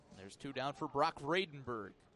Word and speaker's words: Vradenburg: Vredenburg, in case you were wondering. 1-0. Called strike on the outside edge. Makes it 1-1. Vredenburg is spelled V-R-A-D-E-N-B-U-R-G. Vredenburg.